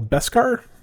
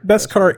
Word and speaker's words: Beskar: You'll also get a Razor Crest gunship and a, how do you say that word, Cole? Beskar? Probably? – Sure. – Beskar ingot hanging flare, an IG series assassin droid dashboard flare, and blurg and bounty hologram.